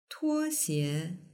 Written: tuoxie

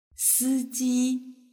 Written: siji

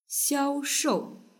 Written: xiaoshou

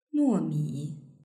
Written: nuomi

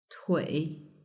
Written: tui

